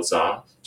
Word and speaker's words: Zha: My Twitter handle is Carl Za, just my name. Carl is spelled with a C, C-A-R-L, and my last name, Z-H-A.